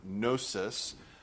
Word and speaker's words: gnosis: Now, in Greek, knowledge is the word gnosis. That has a silent G on the front, though.